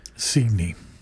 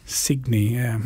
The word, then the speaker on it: Cygni: The track was called SS Cygni, spelt with a C. – Cygni. – Cygni, yeah. – Yeah. As in signet.